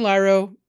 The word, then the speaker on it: Lairo: It's spelled L-A-I-R-O. It's pronounced Lairo, apparently.